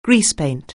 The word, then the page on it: greasepaint: anglais